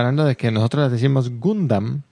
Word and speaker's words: Gundam: Su pronunciación es Gundam, con A.